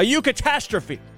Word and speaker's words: eucatastrophe: A eucatastrophe. I'm guessing that's how you pronounce it, like sort of like Europe, but eucatastrophe.